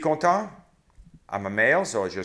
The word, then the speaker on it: content: Content. Don't hear the T.